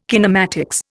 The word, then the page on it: kinematics: Indian